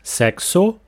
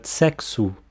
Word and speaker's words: sexo: There's an O at the end, and the vowel O in word final position will almost always sound like U in the Brazilian Portuguese standard pronunciation. So, not sexo, but sexo. To say to have sex, don't use the verb ter, which is the translation of to have.